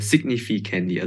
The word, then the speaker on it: Significandy: Äh, Significandy, also von Significant und dann halt noch mein Namen dahinter.